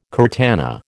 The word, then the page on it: curtana: American English